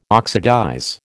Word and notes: oxidize: American English

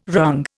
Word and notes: wrung: Indian

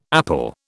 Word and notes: apple: British English

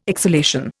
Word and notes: exhalation: Indian

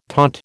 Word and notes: taunt: American English

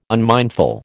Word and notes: unmindful: American English

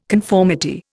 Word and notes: conformity: Indian

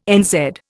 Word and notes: NZ: Indian

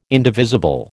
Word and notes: indivisible: American English

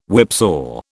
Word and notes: whipsaw: British English

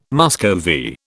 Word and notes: Muscovy: British English

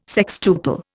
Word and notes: sextuple: Indian

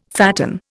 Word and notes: fatten: Indian

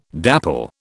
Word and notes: dapple: British English

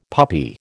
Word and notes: puppy: American English

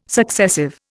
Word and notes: successive: Indian

